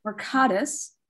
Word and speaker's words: Mercatus: as well as projects such as the Mercatus, am I saying that right? – Mercatus Collective and the Urban League of Portland State of Black Oregon 2015.